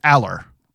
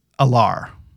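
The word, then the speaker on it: Allar: Adam Brenneman had Drew on, and he asked him how, for the last time, you know, for the record, how do we pronounce your last name? And it was Aller. – Yeah. – So Drew Aller said that's how you pronounce his name.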